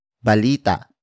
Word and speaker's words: balita: And that is balita. Balita. Balita. Balita is spelled as B-A-L-I-T-A.